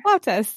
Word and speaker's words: flautas: I've now a new hit with my boyfriend is, I don't know if I'm saying this right, flautas. – Yeah, flautas.